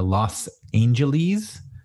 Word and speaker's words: Los Angeles: He's in uh, Los Angeles. That's the correct pronunciation.